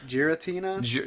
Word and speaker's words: Giratina: And then, you know, that doesn't last very long, because Giratina, I don't know how to say it, Garantia. – Giratina? – Giratina.